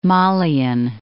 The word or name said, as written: Malian